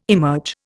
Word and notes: emerge: Indian

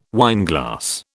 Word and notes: wineglass: British English

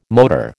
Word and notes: motor: American English

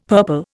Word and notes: purple: Indian

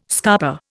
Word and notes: scarper: Indian

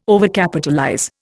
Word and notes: Overcapitalize: Indian

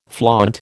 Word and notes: flaunt: American English

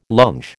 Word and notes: longe: American English

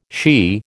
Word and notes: she: American English

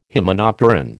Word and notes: hymenopteran: American English

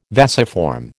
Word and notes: vasiform: American English

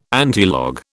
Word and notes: antilog: British English

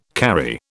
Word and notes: carry: British English

